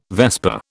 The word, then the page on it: vesper: British English